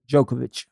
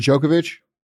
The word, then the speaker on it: Djokovic: Djokovic. – Is it Djokovic? You're right. All right. – Bro, I'm cultured. – All right. I'm just making sure. I thought it was Djokovic.